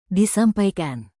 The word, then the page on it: disampaikan: indonesian